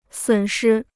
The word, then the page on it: 损失: sǔn shī